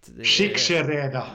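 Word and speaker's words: Csíkszereda: Skiksereda! Ja, det, det gjorde du bra.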